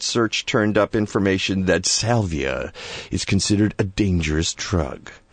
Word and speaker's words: Salvia: That's right. Salvia is how you say it? – Salvia is, yeah, is the general name of the plant, sort of the common name and the genus.